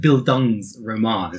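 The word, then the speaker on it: Bildungsroman: Yeah, first of all, it's pronounced Bildungs Roman. It's a German.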